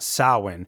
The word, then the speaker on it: Samhain: And if you don't know what Samhain is, it's the Celtic Irish festival spelled out as S-A-M-H-A-I-N, but pronounced Samhain. Phonetically, there's a couple other ways that it's been spelled out, such as S-O-W, as in cow, and then when, W-E-N. But I think of it as S-A-U-W-E-N, Samhain.